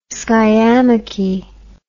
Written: skiamachy

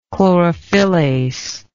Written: chlorophyllase

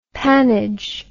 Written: pannage

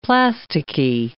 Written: plasticky

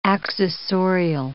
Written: accessorial

